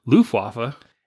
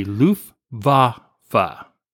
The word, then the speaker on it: Luftwaffe: But anyway, he's saying, listen, love your show, but you guys keep saying Luftwaffe. And he goes, that's not how you say it. It's actually Luftwaffe. How do you say it fast? – Luftwaffe. – Okay. – Something like Luftwaffe. That. Yeah. – But anyway, instead of me saying wah, it's actually a V.